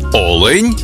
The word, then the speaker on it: олень: в однині, і в множині наголос падає на перший склад – олень та олені.